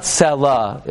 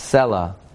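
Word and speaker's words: Selah: It's not Sela, it's Sela.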